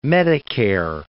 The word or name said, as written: medicare